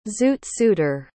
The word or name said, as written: zoot-suiter